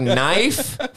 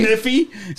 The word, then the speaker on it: knife: It's like knife? Fuck you, K. Like seriously. – Kniffy? – So, Kniffy?